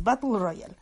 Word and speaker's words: Battle Royale: Ay, no puedo pronunciarlo como lo pronuncian ellos. Es Badoru Royaru. Ese es Battle Royale. Así más o menos lo pronunciaría un japonés ebrio.